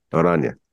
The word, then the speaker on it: Oranje: But you go with the Oranje. Did I say that right? – Oranje.